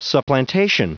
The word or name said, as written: supplantation